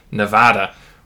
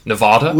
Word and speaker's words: Nevada: Well, Nevada is, and I think that happens because it's really a Spanish name. It means snow-covered mountains, and so if you were pronouncing it in Spanish, you'd probably say Nevada. But I think the early settlers here that were miners and ranchers and whatever probably changed the pronunciation of the word.